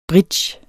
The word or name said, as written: bridge